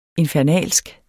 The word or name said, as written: infernalsk